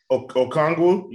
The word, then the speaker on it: Okongwu: I think that's kind of a question mark, but I mean, Anoki, maybe, is that how you say his name? Anoki Ngonwa. I know I totally butchered his name, and I'm sorry, but Okongwu? You talking about Okongwu? – Yeah, that's, there you go.